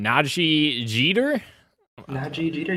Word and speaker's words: Najee Jeter: Spider-Man Miles Morales actor, I'm gonna butcher his name, Najee Jeter, yeah, that's how I would say it.